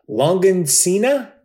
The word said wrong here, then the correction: longanisa: No, wait, nope. That's like John Cena. Yeah, Longancina. Wait, no, I'm still saying it wrong. Longanisa. Longanisa. – Longanisa. – Longanisa. Not John Cena.